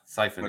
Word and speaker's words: Siphon: Am I saying it right? Is it called a Siphon bot? – Yeah, Siphon. Okay.